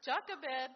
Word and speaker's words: Jochebed: This silly YouTube video went, Jochebed, Jochebed, Jochebed. I said, okay, I know how to pronounce it now. Jochebed, the mother of Moses, hid him for 3 months when Pharaoh decreed all male infants be killed.